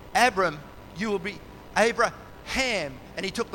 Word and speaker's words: Abraham: And when you put that H in their names, it always, if you say it correctly, it sounds like wind. Abraham.